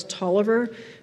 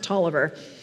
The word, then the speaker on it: Taliaferro: There's actually a great book called The Image in Mind by the philosopher Charles Tolliver, spelled Taliaferro, but pronounced Tolliver, and co-written with a woman called Jill Evans, about the use of images in philosophical discourse.